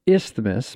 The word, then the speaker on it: isthmus: Madison, Wisconsin sits on an isthmus between isthmus. – How do you say it? – You don't say the TH.